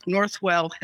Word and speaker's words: Northwell: But ever since then, like Northwell Health, I was just like, how do you pronounce it? Norwell? – Northwell Health. – It is Northwell Health.